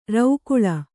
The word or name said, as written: ರೌಕುಳಿ